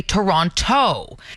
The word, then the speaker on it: Toronto: If you're from Toronto, you tend to leave out that second T. If you're not from there, you'll say Toronto.